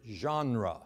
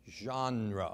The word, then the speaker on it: genre: Genre, okay? That word is spelled G-E-N-R-E. It's a French word, actually. Genre. So spell it with me. G-E-N-R-E.